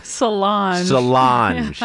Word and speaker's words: Solange: What's Beyonce's sister's name? – Solange. – Yeah. Because you could pronounce that a number of ways. – Yeah, you probably could. I think it's actually French. I think it's a legitimate French name.